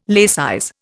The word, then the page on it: laicize: Indian